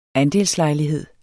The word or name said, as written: ANDELSLEJLIGHED